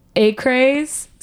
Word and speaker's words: Acraze: I don't even know how to pronounce this. – Acraze. So him. – It's A-C-R-A-Z-E.